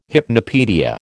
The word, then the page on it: hypnopaedia: American English